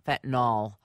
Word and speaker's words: fentanyl: A texter writes just a minute ago, it's fentanyl, not fentanyl. I know that. I realize that is a pronunciation issue, but I do think that You tell me how you say it.